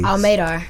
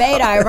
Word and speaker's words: Almadar: Almadar. – Thank you, because I was finna mess it up. – Almadar. Rhymes with radar.